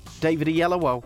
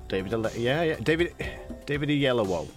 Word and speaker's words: Oyelowo: David Oyelowo. – David Oyelowo.